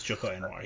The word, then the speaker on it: Joko Anwar: All right, so now we're going to jump into Satan's Slave, which was a 2017 movie directed by Joko Anwar. Did I say that right? – I believe you did. I believe it's Joko Anwar, yeah.